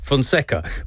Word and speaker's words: Fonseca: Joao Fonseca, sorry, I've butchered that, Fonseca produced a stunning fight back to win a 5-set epic in the French Open third round.